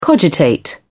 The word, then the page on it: cogitate: British English